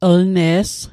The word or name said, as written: lne's